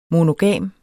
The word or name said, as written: monogam